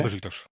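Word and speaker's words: Beşiktaş: Und steht im Moment auf Platz 3 hinter Galatasaray und Fenerbahce, aber okay. Vor Besiktas. – Ne, und genau so spricht man die Vereine aus.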